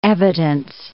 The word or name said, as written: evidence